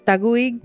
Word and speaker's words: Taguig: How do you say that? – Taguig? – Taguig? – Yeah.